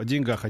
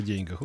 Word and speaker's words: деньгах: Вот я, например, знаю, что лучше говорить о деньгах, а не о деньгах. Ну, не, не лезет это о деньгах.